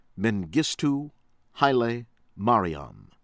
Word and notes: Haile-Mariam Mengistu: American English; Ethiopia

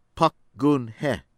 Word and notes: Park Geun-Hye: American English

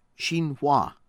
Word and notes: Xinhua: American English; from China

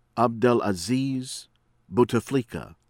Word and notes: Abdelaziz Bouteflika: American English

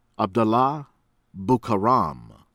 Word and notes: Abdala Bucaram: American English

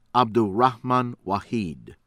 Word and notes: Abdurrahman Wahid: American English

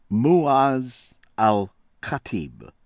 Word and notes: Moaz Al-Khatib: American English

